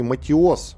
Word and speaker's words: Матиос: Главный военный прокурор Украины Анатолий Матиос, вот правильное ударение в фамилии,